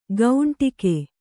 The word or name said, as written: ಗೌಂಟಿಗೆ